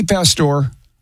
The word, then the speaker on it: Pastore: Vinnie Pastore is the Italian pronunciation.